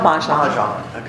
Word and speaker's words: mahjong: Did I say that right, Mahjong? – Mahjong.